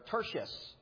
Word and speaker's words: Tertius: Next, he mentions is not Paul mentioning it, but this man named Tertius, or Tertius is another way to pronounce that.